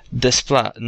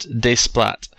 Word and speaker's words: Desplat: And she's writing in to say to us that I did a little bit of research, and if I'm not mistaken, his name is pronounced Desplat, not Desplat. Remember, the T is usually silent in French words when it's at the very end.